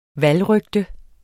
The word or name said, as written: valgrygte